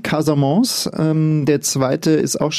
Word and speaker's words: Casamance: Genau, Casamance schreibt man auch mit C, aber man spricht mit K.